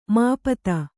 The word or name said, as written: ಮಾಪತಿ